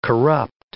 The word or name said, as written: corrupt